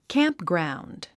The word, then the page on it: campground: キャンプグラウンド